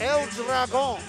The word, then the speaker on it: El Dragon: And I like the way you said that, El Dragon. – El Dragon. – You pronounced it absolutely correctly.